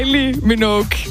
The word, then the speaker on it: Kylie Minogue: Det er Kylie Minogue.